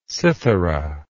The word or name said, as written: cythera